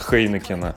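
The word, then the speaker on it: Heineken: Из-под Хейнекена. Да, кстати, правильно говорить именно Хейнекен, потому что класс.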